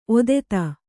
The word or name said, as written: ಒದೆತ